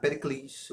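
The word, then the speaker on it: Pericles (Περικλής): É isso mesmo, Pericles. O, o acento é no, na última sílaba.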